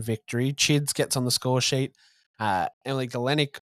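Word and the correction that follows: Gielnik: notches the winner in 90+3. – Gilnick. – Thank you. I think I said it wrong last week too. Why didn't you guys pick me up on this last week? Gilnick. Sorry, Emily. My bad.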